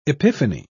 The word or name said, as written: epiphany